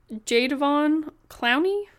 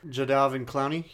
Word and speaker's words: Jadeveon Clowney: Jadeveon Clowney? – Jadeveon Clowney, yeah, or Jadeveon Clowney.